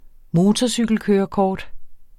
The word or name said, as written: motorcykelkørekort